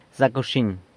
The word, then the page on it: Zac wxiny!: San Lucas Quiaviní